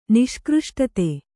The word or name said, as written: ನಿಷ್ಕøಷ್ಟತೆ